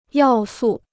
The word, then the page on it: 要素: yào sù